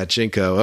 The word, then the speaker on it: JNCO: Oh, yeah, Jinko. Okay, I think that's the winner. – Please spell it J-N-C-O, like the jean company.